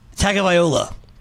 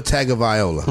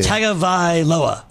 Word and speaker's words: Tagovailoa: It's actually not that hard. It's literally written as it sounds. It's Tua Tagovailoa. – Tua Tagovailoa. – No, I said that wrong. Yeah. Tagovailoa. That's what it is.